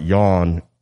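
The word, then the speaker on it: Jan: Wrote, clarification, J in German is pronounced like a Y. – What? – So the name Yawn in German is pronounced like yawn in English. Yawn. I said jag or something like that.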